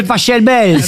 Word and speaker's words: Pachelbel: Canelloni, c'est Pachelbel. – S'il est allemand, ça se dit Pachelbel. – Exactement, ouais. Ça pourrait se dire éventuellement Pachelbel, ouais.